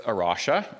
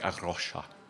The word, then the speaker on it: Arrocha: You have to do this great rolling R, like Arrocha, right? It means the rock in Portuguese, and it's a dual use of the term rock.